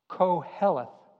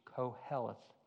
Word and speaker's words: Qoheleth: Koheleth. Q-O-H-E-L-E-T-H. Koheleth.